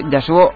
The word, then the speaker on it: Yasuhiro: Bueno, se dice bien si no pronunciamos la H intercalada, pero igualmente Yasuo